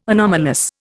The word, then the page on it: anomalous: Indian